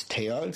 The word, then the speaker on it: Theo: It was challenging at times, certain pronunciations, I had a teammate named Theo, but over there they don't pronounce the H, so it's T-O. – T-O, and I remember I was screaming him, Theo, Theo, Theo, and he's just not looking at me.